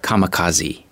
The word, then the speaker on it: kamikaze: Yes, in the States we say kamikaze. – Kamikaze. – Right. – Karaoke とまた変わる感じですね。 – It's something like that, or Japanese sake for sake. In English we can't say ke at the end of a word.